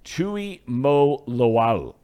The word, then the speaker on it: Tuimoloau: Tuimoloau. You pronounce every single vowel when you're pronouncing Samoan players' names.